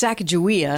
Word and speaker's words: Sacagawea: It's Sacagawea.